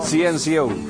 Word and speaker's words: CNCO: Que son 5, pero sin la I. – 5 sin la I. – Eso. 5 NCO. Da lo mismo. – CNCO.